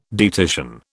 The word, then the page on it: dietitian: British English